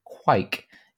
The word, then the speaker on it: kvik: The inclusion of the term kvik, but it's actually pronounced quike, yeast.